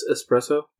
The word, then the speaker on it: espresso: Okay, do you know how to pronounce espresso? There's no X.